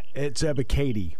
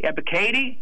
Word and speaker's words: Ebiketie: And if I mispronounce his name, I apologize. Arnold Ebiketie? – It's Ebiketie. – Ebiketie? Thank you.